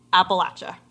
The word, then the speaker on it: Appalachia: And she says that it is definitively Appalachia. So that is how you say it.